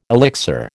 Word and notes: elixir: American English

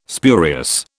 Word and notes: spurious: British English

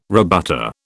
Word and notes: rebutter: British English

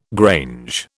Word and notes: grange: British English

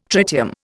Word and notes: tritium: Indian

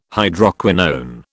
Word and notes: Hydroquinone: British English

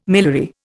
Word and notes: miliary: Indian

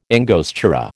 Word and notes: angostura: American English

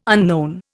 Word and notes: unknown: Indian

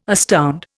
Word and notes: astound: Indian

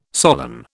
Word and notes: solan: British English

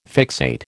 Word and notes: fixate: American English

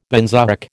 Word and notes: benzoic: American English

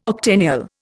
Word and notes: octennial: Indian

